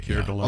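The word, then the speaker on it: Keir Dullea: Kier Dulia? – No, Kier Dulay. – Oh, that's how you say it? – Yeah.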